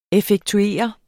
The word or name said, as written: effektuere